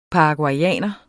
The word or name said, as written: paraguayer